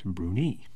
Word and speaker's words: Brunei: The most frequently mispronounced country names, the ones nobody got, are the Seychelles, which actually is pronounced Seychelles, and Brunei, which is actually pronounced Brunei.